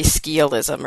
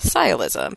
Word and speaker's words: sciolism: It doesn't, it again doesn't sound how it looks like it should sound, because it looks like it should be skialism or skialism, because it's SC, but it's pronounced sialism.